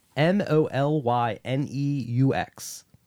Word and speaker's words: Molyneux: How do you say that name? L O M O M O L Y N E U X. Molyneux. Molyneux? Yeah.